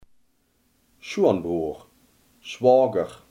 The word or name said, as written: schoonbroer